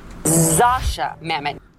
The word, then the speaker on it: Zosia Mamet: So the correct way to pronounce Zosia Mamet is... – Zosia Mamet.